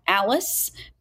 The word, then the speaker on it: Allis: It's at Alice, but it's just, my name is spelled A-L-L-I-S.